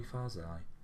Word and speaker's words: Yousafzai: Yousafzai.